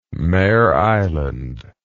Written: Mare Island

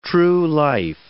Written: true-life

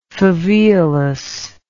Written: faveolus

